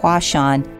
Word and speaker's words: Huashan: The Chinese pronunciation for the Japanese characters used in this name sound like Huashan, and hence the current name for the Creative Park was born.